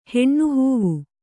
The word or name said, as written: ಹೆಣ್ಣುಹೂವು